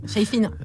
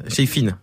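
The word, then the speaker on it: Shayfeen: Donc ouais, mais je, connaissais à Chafnik le, groupe de trappe marocaine. – Chayfine. – Chayfine. Bah, je, le connaissais mal donc. Fais attention avec les prononciations, c'est important grec.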